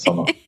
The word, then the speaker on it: salah: And it is one of the 5 pillars of Islam, is prayer, or salah. Is that the right way to say it? – Yes, you say it right. That's salah. Yeah.